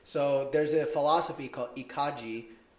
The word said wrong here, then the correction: Ikigai: I don't know if I'm saying it right. – Ikigai? – Ikigai. Yeah, there we go.